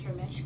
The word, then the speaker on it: Ramesh: My name is Ramesh Kosi. – And how do we spell that? – R-A-M-E-S-H. And then K-A-U-R -S-I-N-G-H.